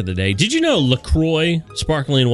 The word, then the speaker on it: LaCroix: Officially, it's pronounced, as I said, LaCroix, so it rhymes with enjoy.